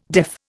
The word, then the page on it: deaf: Indian